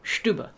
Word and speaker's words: Stubbe: There is a specific case that is sort of prominent that occurs in 1589 of, this is in Germany, so you can tell me if I'm pronouncing this right, a fellow by the name of Peter Stube, S-T-U-B-B-E? – Mm-hmm. Yeah, Stube. Yep. – Stube, okay.